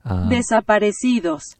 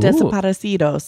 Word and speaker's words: Desaparecidos: Desaparecidos. – Ooh. Desaparecidos. – Play it again. – Desaparecidos. – I was saying it right, I think, just in a shitty American accent.